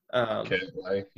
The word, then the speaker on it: Cantlay: I guess I like Cantlay. – Yep. – I don't know if I pronounced that correctly. – Cantlay, yep.